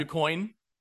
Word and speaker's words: DuQuoin: Is it DuQuoin? Is that how you pronounce that? – DuQuoin.